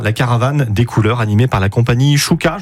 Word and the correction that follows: Souka: je le prononce bien ? – Souka de Souka.